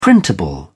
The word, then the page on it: printable: English; British English